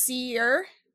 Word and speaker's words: Sear: We have Cole Sear, which is pronounced Seer.